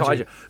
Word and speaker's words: kaiju: that how do you pronounce it? Kaijo. – Uh, kai, yeah, kaiju. – kaiju. That kaiju,